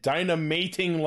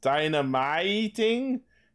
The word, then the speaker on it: Dynamating: We're getting DX keys of Trapping Spider, Hopping Kangaroo, and Dynamating Lion. That's right, it's spelled Dynamating. Dynamiting? It's definitely not dynamiting.